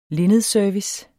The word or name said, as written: linnedservice